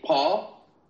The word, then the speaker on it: Paugh: Owen, how do you pronounce P-A-U-G-H? – P-A-U-G-H, Paul.